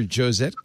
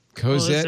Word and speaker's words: Cosette: Cosette.